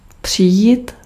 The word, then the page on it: komen: [koː.mǝⁿ]